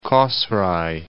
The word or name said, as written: Kosrae